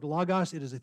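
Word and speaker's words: Logos (λόγος): Logos is the proper way to pronounce this word because it's omicron, omicron, and not omega, omega.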